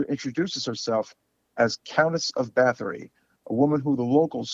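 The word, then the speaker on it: Báthory: And the way they pronounce it is Bathory. – Bathory, yes.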